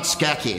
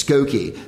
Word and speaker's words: Skokie: And he was like, "It's not Skeki, it's Skokie."